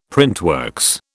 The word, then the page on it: printworks: British English